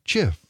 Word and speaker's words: GIF: They dubbed it the GIF, pronounced with a soft G, like GIF, the peanut butter.